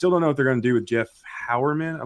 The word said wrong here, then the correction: Heuerman: I believe is that how you pronounce his Heuerman. – Last name? – Heuerman. Yeah, Heuerman.